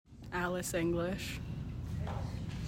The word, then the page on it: Alice English: AL iss ING glish